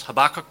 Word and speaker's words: Habakkuk: One of Jeremiah's contemporaries was a man called Habakkuk. Apparently the right way to pronounce that is Habakkuk, but that sounds so wildly pretentious I refuse to use it.